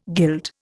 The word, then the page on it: guild: Indian